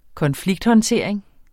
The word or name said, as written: konflikthåndtering